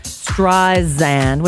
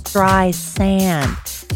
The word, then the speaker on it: Streisand: Barbara Streisand told NPR that Siri mispronounces her name, Streisand, when it's supposed to be a soft S, Streisand.